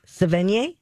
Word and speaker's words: Sevigny: Chloe Sevigny, I think that's Sevigny. – How you say it. Sevigny? – Mm-hmm.